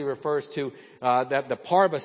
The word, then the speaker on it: parve: It looks like parve, but it's pronounced parva.